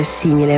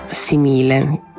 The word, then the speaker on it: simile: È per questo che a un certo punto la parola simile non si dovrebbe leggere simile, ma simile, con l'accento piano sulla seconda sillaba.